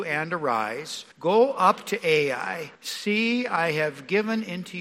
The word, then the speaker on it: Ai: And I'm going to pronounce that word, that name of the city, Ai, just as it sounds, Ai. Some people call it A, but we can say Ai also.